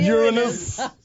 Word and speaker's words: Uranus: Uranus is how it's pronounced. – Uranus.